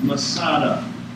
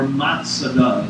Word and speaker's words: Masada: including the stronghold of Masada, or Matsada, as some people like to pronounce it.